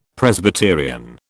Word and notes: Presbyterian: British English

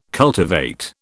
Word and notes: cultivate: British English

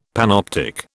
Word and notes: panoptic: British English

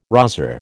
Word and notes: rozzer: American English

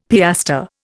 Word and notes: piastre: Indian

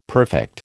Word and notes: perfect: American English

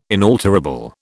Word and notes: inalterable: British English

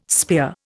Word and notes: spear: Indian

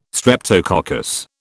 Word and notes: streptococcus: British English

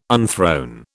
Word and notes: unthrone: British English

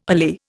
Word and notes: allay: Indian

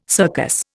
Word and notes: circus: Indian

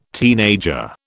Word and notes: teenager: British English

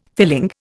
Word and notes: filling: Indian

